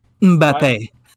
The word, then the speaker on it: Mbappe: Mbap. – Close. – What? – Close. – Ah. Why? – He's French. – Mbappe. – Kylian Mbappe.